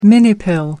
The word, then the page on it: minipill: American English